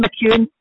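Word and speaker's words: McEwan: Or that would have been McEwen at the time.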